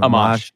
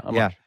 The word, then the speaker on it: Amash: Amash. Yeah, Amash. Yeah. – Was he decent or was he a possibility? – He's one of the more libertarian congressmen in Congress. I am glad that Justin Amash entered the Libertarian Party.